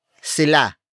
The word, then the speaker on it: sila: The next word is sila. Sila. Sila. Sila is spelled as S-I-L-A.